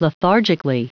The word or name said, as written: lethargically